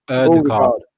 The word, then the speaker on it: Ødegaard: I think it's pronounced Ødegaard, is it? – Ødegaard. Yeah.